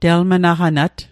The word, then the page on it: telmnaqanat: Mi'gmaq